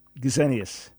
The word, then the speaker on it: Gesenius: Is his name pronounced Justinius or Justinius? I've heard a different one. – Gesenius. The Gesenius. S in German is a Z in sound, so Gesenius, okay.